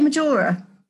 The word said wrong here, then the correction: Majala: Majala. – Majala.